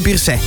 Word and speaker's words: Pierset: Oh, dat mag je een T niet uitspreken, hè? Freddy Pierset.